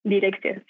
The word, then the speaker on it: Direxio: ¿Cómo lo pronuncias? ¿Direxio? Direxio, sí.